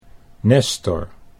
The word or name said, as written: Nestor